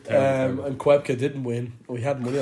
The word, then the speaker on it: Koepka: Yeah, this is what you call Koepka? – Koepka. – That's his name.